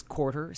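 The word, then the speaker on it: Courter: My real name is Quarter Simmons, and a quarter is a weird name. It's spelled like a tennis court, ER.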